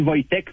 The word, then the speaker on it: Wojtek: Je suis nom du Wojtek qui s'écrit W-O-J-T-E-K, comme le W se prononce par V, mais chez nous, c'est Wojciech.